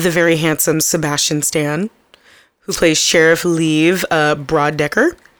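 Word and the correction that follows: Bodecker: Or Bo Decker. I said that wrong. There was no R there.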